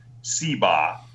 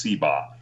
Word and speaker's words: Seebach: His name is Sean Seba. Now, his last name is spelled S-E-E-B-A-C-H, but it's pronounced Seba.